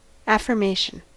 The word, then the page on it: affirmation: American English